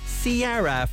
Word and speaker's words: Ciara: Missy Elliott teaming up with, it's spelled Kira, but it's pronounced Ciara, from her album My Goodies.